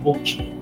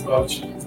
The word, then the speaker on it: Bauchi: So, what's the pronunciation of that state? They say Bauchi or Bochi? Bauchi, no man, it's Bauchi. Bauchi, alright.